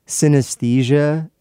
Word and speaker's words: synaesthesia: The new word invention comes from combining the word cinema, synesthesia, S-Y-N-A-E-S-T-H-E-S-I-A, and another word that sounds exactly the same, synesthesia, but it's spelled C-O-E-N-A-E-S-T-H-E-S-I-A,